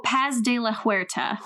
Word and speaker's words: Paz de la Huerta: Oh, Paz de la Huerta.